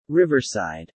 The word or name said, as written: riverside